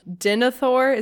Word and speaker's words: Denethor: I will say, so I know that Denethor, is that how you say his name? – Yeah.